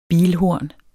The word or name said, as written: bilhorn